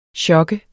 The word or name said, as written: sjok